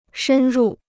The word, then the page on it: 深入: shēn rù